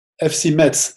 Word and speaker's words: Metz: You know, How do you pr- pronounce the team again? – FC Metz, M E T Z.